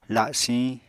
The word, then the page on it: lasiñ: apatani